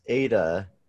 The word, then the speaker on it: Aida: It's pronounced Ada, but apparently what I've heard is that when my grandfather came over here, when he got to Ellis Island, they asked him his name, and he pronounced it Aida. And then they changed it there. The guy's like, ah, let's just say Ada.